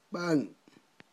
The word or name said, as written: b'a'n